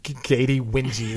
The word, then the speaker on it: Katy Winge: Katie Wingy. – Like that. – Wingy is how you pronounce it.